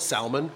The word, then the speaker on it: salmon: For whatever reason, I call it salmon, and everybody makes fun of me. – Okay. – So I pronounce the long L.